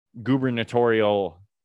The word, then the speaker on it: gubernatorial: There was just a gubernatorial, I think I said that right, election. – It sounds wrong, but you said it right.